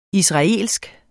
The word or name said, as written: israelsk